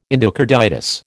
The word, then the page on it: endocarditis: American English